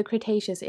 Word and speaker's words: Cretaceous: oh no, I can't pronounce this, the Cretaceous era, which is 65 to 145 million years ago. No, I need to check up that pronunciation. Hold on one second. Okay, Wiki tells me I pronounced it correctly.